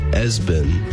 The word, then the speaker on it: ESPN: Another one was ESPN, pronounced Esben.